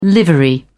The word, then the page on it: livery: British English